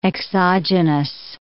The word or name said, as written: exogenous